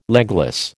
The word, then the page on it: legless: American English